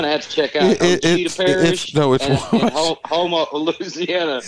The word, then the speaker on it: Ouachita: No, it's pronounced Ouachita. – No, my ass. That thing says Ouachita. – No, it's not, but it's pronounced Ouachita. I'm telling you. It's not Ouachita or whatever.